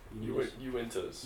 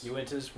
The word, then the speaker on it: Uintas: Uintas. – Uintas, where there is no one else there.